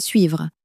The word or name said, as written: suivre